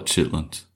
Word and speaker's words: chitterlings: Maybe just in the South we call it chitlins. Yeah, we probably left the R sound out.